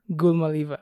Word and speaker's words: Gulamaliyeva: Gulamaliyeva.